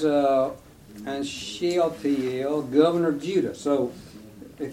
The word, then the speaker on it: Shealtiel: Shealtiel, there you go.